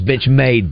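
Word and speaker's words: Benchmade: I think I said benchmark. It's bench made, Bill.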